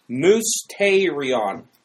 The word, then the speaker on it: mysterion: M-O-O-S, And then it's Tay. – Which is spelled real. – And then we have Re. On, right? That's the actual pronunciation part, okay? Mousterion, okay?